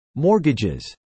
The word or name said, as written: mortgages